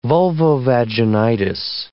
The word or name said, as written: vulvovaginitis